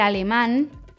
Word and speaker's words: alemán: Manchmal ändern sich die Akzentsetzungen je nach Numerus, zum Beispiel el alemán, Betonung am Ende, weil es mit Konsonant auf n endet, deshalb kommt ein Betonungszeichen.